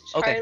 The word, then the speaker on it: Charlie: It's Charlie.